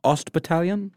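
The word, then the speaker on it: Ost-Bataillon: Yang was conscripted to fight in that East Battalion, or how do you say it? – Ost Battalion. – Perfect.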